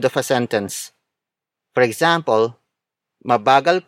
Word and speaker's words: mabagal: Mabagal kumain si Chris.